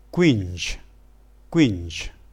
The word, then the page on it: quing: dialetto